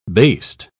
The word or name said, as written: based